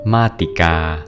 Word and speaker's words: มาติกา: สวัสดีครับพบกับรายการคำวัดวันนี้ขอเสนอคำว่ามาติกาคำว่ามาติกาสะกดด้วยมอม้าสละอาต่อเต่าสระอีกอไก่สละอาอ่านว่ามาติกา